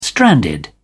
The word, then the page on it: stranded: British English